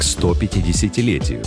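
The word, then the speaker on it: стопятидесятилетию: Очень многие люди говорят «ста» вместо «сто». Конечно, ударение в этом слове падает на седьмой слог – к 150-летию. Однако не стоит забывать, что после «т» мы говорим не «а», а четкое такое «о».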